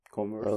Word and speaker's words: Converse: Converse böyle mi okunuyor? Converse. – Evet, evet, Türkiye'de çok Converse.